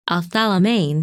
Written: Althalimain

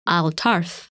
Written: Altarf

